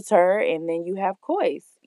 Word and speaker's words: Cois: It's pronounced as cois. A lot of people get the name mixed up. They say quees and things of that sort.